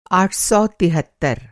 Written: आठ सौ तिहत्तर

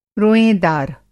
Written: रोयेंदार

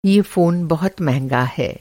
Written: महँगा

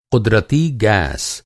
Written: क़ुदरती

